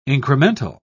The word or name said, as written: incremental